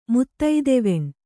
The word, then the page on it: ಮುತ್ತೈದೆವೆಣ್: kannada